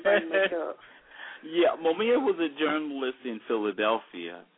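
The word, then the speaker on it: Mumia: Momaya Abu-Jamal. – Oh, his name is Momia. Momia Abu-Jamal.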